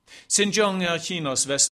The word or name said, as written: Xinjiang